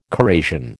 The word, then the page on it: corrasion: American English